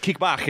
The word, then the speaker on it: Kickbach: It's pronounced Kickbach. It's German. – Oh, Kickbach. – Yeah, it's not Kickback.